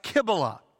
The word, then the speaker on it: Cybele: Her name here, Cybele, is pronounced Kybele.